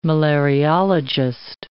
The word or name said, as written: malariologist